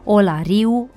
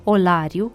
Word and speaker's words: Olariu: Din păcate însă, în ultima vreme se întâlnesc mai frecvent pronunțări cu accentul pus greșit pe i, ca în exemplele olariu în loc de olariu, ciubotariu în loc de ciubotariu, ferariu în loc de ferariu.